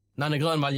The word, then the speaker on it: Nanne Grönvall: Nanne Grönvall. – Nanne Grönvall, yeah.